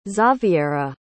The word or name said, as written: zaviera